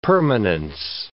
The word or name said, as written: permanence